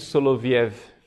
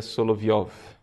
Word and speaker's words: Soloviev: Então, o cardeal Biffi nos fala, né, de Vladimir Soloviev, a pronúncia, na verdade, seria Soloviov.